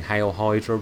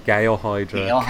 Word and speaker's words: Hail Hydra: And what I like about it is all through the issue, he keeps saying Hail Hydra wrong. He keeps mispronouncing it. – Gale Hydra. – Keeps saying it wrong every time.